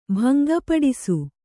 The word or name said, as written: ಭಂಗಪಡಿಸು